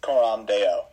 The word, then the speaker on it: CoramDeo: Yeah, the it's Latin. So the official Latin pronunciation is CoramDeo.